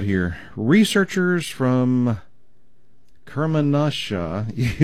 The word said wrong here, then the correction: Kermanshah: University. I know I butcher these names. I'm so sorry. So, Kermanshah University of Medical Sciences in Iran,